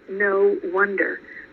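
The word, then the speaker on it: Know Wonder: And the new book is called No Wonder, but it's spelled K-N-O-W.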